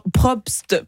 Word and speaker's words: Probst: Mais sauf que contrairement à vous, Nicolas, Caroline est née en 1953 sous le crayon de Pierre Brobst. Et figurez-vous qu'au départ, Hachette lui avait demandé de dessiner un garçon. Raté. Pierre Probst, pardon pour la prononciation, c'est pas évident.